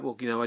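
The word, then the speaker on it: اوکیناوا (沖縄): اوکیناوا جا چکے ہیں اوکیناوا کا درست جاپانی تلفظ ہے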